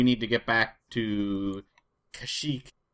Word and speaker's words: Kashyyyk: But anyways, they're headed to Kashyyyk. That's how I pronounce it, right? – Kashyyyk. – Yeah, they pronounce it weird. Kashyyyk is the official pronunciation.